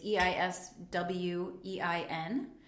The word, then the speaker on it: Eiswein: Now, it is spelled two different ways one mostly in Europe, which is E I S W E I N. It's Eiswein. And then ice wine, like us Americans who like to do it all phonetically.